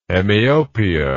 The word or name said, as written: hemiopia